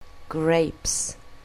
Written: grapes

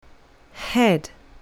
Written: head

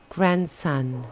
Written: grandson

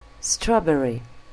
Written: strawberry

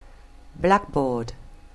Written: blackboard

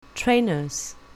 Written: trainers

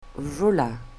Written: ruler

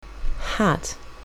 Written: hat

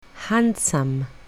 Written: handsome

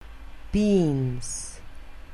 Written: beans

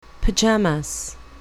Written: pyjamas

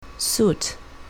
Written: suit

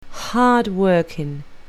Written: hard-working

